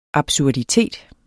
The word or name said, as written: absurditet